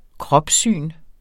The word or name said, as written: kropssyn